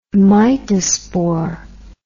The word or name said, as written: mitospore